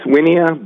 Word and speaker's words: Wynia: Am I pronouncing that correctly? – I believe it's pronounced Winia, but I mean, it's kind of pronounced potato or potato, you know. I'm going to go with Winia.